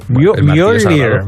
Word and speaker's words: Mjolnir: Mjolnir, Mjolnir.